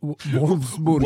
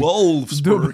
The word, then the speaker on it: Wolfsburg: Och i den andra Champions League-semifinalen eh, så var det Wol Wolfsburg. Wolfsburg. – Wolfsburg. – Dubbel Galna W som man har lite problem som svensk att uttala eh, mot eh, Arsenal.